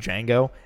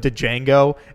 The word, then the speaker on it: Django: You remember the point in the movie where A, everybody calls him Django, and B, there's the racist hillbilly gun toter that calls him Django, and then he shoots him, and he's like, the D is silent, hillbilly, and then fucking kills him.